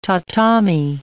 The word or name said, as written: tatami